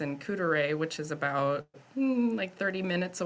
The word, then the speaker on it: Couderay: But now let's turn to Couderay. As I was told, that's how you say it, Couderay, to, it's a house, it's a tourist attraction that I'm not surprised exists with, because of Wisconsin's proximity to Chicago.